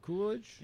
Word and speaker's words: Coolidge: Coolidge. Is that how you say it? – Yeah, Coolidge.